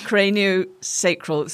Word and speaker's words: craniosacral: You also used the phrase, forgive the pronunciation, cranioscopial. – Craniosacral. So the cranium being your head. – Okay. – The sacrum being at the bottom of your spine.